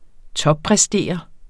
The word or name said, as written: toppræstere